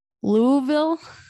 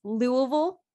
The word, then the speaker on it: Louisville: Now, did I say that right? – No, but I'm glad you gave it a try. It's Louisville. – Louisville. – Louisville. – Sure. – You know, just kind of like ease into it, Louisville. Swallow it down, Louisville.